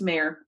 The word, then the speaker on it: Meagher: Her last name is spelled M-E-A-G-H-E-R, but I've looked it up a couple times and it's basically just Mayer, as in like, you know, M-E-Y-E-R in our stretch of the woods.